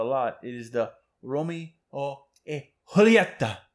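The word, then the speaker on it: Romeo y Julieta: But this is the Robusto Romeo and Juliet 1875, otherwise pronounced as Romeo e Julieta.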